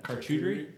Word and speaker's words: charcuterie: How do you say that? Charcuterie? – You got that.